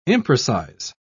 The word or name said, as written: imprecise